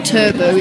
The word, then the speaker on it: Turbo: For example, in a place called Turbo, T-U-R-B-O, Turbo, it's pronounced Turbo, which is based in Colombia, it's too dangerous for the ships to actually dock by the harbour.